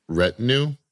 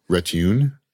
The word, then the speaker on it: retinue: I got yelled at for this. Not yelled at. I got properly corrected. 43 years of age. Was pronouncing a word wrong the whole time. – What were you even saying? – Retune.